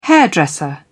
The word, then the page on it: hairdresser: British English